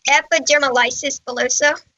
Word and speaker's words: epidermolysis bullosa: All right. So, what is the proper pronunciation? – Yeah. How do you say it? – I think it's epidermolysis bullosa. So you were really close.